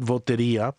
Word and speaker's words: Votería: We called it Votería to rhyme with Lotería because that's a big deal in the Mexican and Central American communities here in the West.